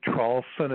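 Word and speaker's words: Traulsen: Yes, my name is Hugh, H-U-G-H. The last name is Trawlson. It's spelled T like Tom, R-A-U-L-S-E-N like Nancy.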